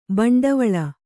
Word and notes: ಭಿಂಡಿವಾಲ: bhiṇḍivāla

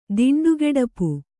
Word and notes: ದಿಂಡುಗೆಡಪು: Kannada

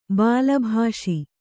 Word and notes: ಬಾಲಭಾಷಿ: Kannada

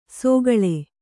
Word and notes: ಸೋಗಳೆ: sōgaḷe

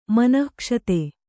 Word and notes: ಮನಃಕ್ಷತ: kannada

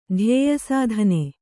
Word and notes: ಧ್ಯೇಯಸಾಧನೆ: Kannada

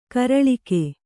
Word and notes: ಕೂರಲಗು: kūralagu

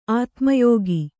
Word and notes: ಆತ್ಮಯೋಗ: ātmayōga

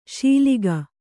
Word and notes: ಶೀಲಿಗ: Kannada

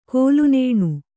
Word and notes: ಕೋಲುನೇಣು: Kannada

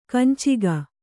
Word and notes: ಕಂಚುಕ: kancuka

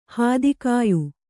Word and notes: ಹತ್ತಿಕಾಯಿ: hatti kāyi

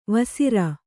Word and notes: ವೇಸರ: kannada

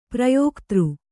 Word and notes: ಪ್ರಯೋಕ್ತೃ: prayōktř

